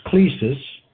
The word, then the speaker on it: klesis: To name, N-A-M-E. The noun is klesis, K-L-E, long E, S-I-S, and it means calling or invitation.